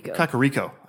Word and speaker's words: Kakariko: Kakariko.